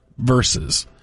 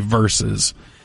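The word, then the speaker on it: versus: And that is that Tom is using the same word that I hear a lot of people saying these days, which is verse, when they mean verses. The old pronunciation of the word used to be verses, and now people just say verse.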